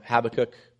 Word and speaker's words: Habakkuk: God does not delay in his coming, as the author quotes from Habakkuk. That is the proper way to say it. Don't argue with me.